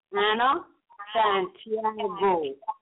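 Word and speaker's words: Ana Santiago: My name is Anna Santiago. – Go ahead and spell it for the audience. – My name is A-N-A.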